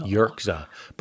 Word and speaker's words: Yerxa: Y-E-R, pretend it's a K instead of an X, and then just Z. – Yerxa.